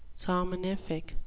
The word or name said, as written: somnific